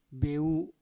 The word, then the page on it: beu: mpur